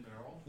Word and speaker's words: Beryl: Beneath us is Queen, I say her name wrong, Beryl, the Sailor Moon, evil queen. – Beryl? – Yeah, Beryl, thank you so much. Below us on the unicorn of the cutie mark is Queen Beryl, is a Borg.